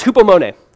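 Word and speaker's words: hupomone (ὑπομονή): It's this Greek word that it's pronounced hupomone. All right, if you want hupomone, you can write that down. Hupomone just means to remain under.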